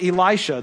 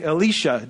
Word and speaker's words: Elisha: or as one said to me this morning, Elisha, depending on if you're trying to pronounce it like a Hebrew, or you're just trying to read the Bible out loud.